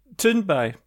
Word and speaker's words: Thunberg: How do you say it? – Thunberg. So ignore the H.